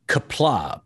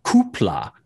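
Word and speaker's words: Qapla': So he he says the typical Klingon greeting, which is kapla, but he doesn't pronounce it like that. He says kupla, so, and I sat there going, really?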